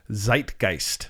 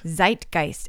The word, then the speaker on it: Zeitgeist: She said, over the past decade, and this is Brené Brown, I've witnessed major shifts in the, how do you say that word? – Zeitgeist. – Zeitgeist. And you looked that word up. What is it? – I did. Zeitgeist.